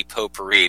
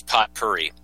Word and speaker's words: potpourri: Pronounced correctly, it would be potpourri, but I love pronouncing it incorrectly because when I was a kid growing up in like the sticks, I thought it was potpourri.